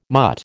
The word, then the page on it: mutt: American English